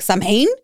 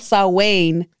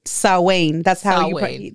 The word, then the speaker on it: Samhain: It's called, it's spelled Samhain. So, of course, my paisa ass was like, Samhain? And then, but then I saw it later. It's called Sawain. Like, Sawane. Sawain. That's how you pronounce it.